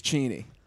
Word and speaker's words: Cheney: It's Cheney.